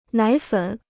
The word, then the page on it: 奶粉: nǎi fěn